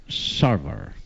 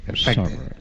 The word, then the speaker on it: Sarver: Sarver. – Bueno, ahí. Sarver, de Phoenix, Arizona.